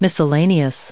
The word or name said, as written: miscellaneous